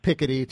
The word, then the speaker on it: Piketty: And for people who don't know, as I'm trying to, you know, approximate the French pronunciation here, the name is P-I-K-E-T-T-Y. So it looks like Piketty to an English speaker, sort of Piketty if you're doing it in French.